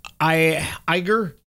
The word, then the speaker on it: Uygur: His name is Cenk Uygur. I think that's how you pronounce his last name, Uygur.